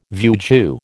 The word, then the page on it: vieux jeu: American English